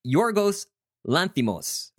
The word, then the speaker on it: Yorgos Lanthimos: And Yorgos Lanthimos, right? That's how you pronounce his name.